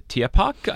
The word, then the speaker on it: Tierpark: Now, on arrival in Hamburg, the trio, so Bonnie, Girano and Durandera, they're set to perform at the Tierpark, I think I pronounced that correctly.